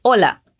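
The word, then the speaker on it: hola: E o terceiro som da sua aula de pronúncia foi o da letra H, que na verdade não tem som em espanhol. Não importa a posição dele na palavra. A primeira palavra que eu te ensinei foi Olá. Repete para mim. Olá.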